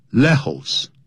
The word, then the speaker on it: lejos: 所以西班牙人发音是很简单，只有少数，像呢 j 你要念成不能念成 j，要念成 h 的音。所以呢，lejos。 L-E-J-O-S 这是西班牙文，不能念成 Lejos。